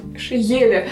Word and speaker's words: Шееле: в том же 18 веке жил очень хороший химик, как Шейеле, Шееле? – Ше, Специально себе поставила ударение, все равно ошиблась.